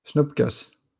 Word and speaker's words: snoepkes: What do you call it in Belgium? Spoendekus? – Snoepkes. – Oh, Snoepkes. That's right.